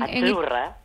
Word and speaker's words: azzurra: Azurra. – Azurra. Mira, ¿ves? – Azurra. Es que... Sí, como porque pizza. La Z no es la Z como es... decimos en catalán tan sonora, no.